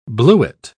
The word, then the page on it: bluet: British English